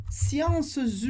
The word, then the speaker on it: science: Mas sempre que a gente tiver S e E no final da palavra, a gente não pronuncia nenhuma das duas letras. Por isso a gente fala science, science, sem o S e sem o E.